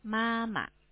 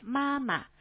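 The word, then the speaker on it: 妈妈: 엄마, 마마, 마마. – 네, 마마. 그 마는 그 첫 번째 마 일성, 두 번째는 그 경성이에요. 네, 그 발음도 같은데 그 성조 좀 달라요.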